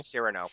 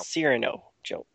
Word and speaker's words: Cyrano: But I did like seeing Cirio Jones. Cirio Jones. How do you say his name? – Cyrano. – Cyrano Jones. – Like Cyrano de Bergerac.